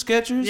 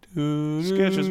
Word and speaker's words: Skechers: The T is silent. – Skechers, what are you talking about? Skechers, no T.